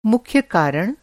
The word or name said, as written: मुख्य रूप से